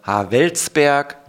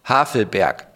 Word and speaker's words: Havelberg: Ich habe mal Havelsberg gesagt, mit W gesprochen, oder Havelberg besser gesagt, und dann Havelsberg mit S, das ist alles nicht richtig.